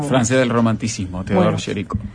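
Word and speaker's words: Théodore Géricault: Theodore Géricault, creo que es el nombre, pero yo lo debo pronunciar como... – Está, perfecto.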